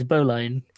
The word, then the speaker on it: bowline: Yeah, it's also so clearly spelt bowline. – Yeah, it's bowline. – Like, it's spelt bow like bow. – Yep. – And then line like line.